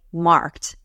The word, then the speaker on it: markt: So a market is very close to the English word, except we don't do that E and we just say marked as a one syllable word.